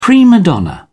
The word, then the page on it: prima donna: British English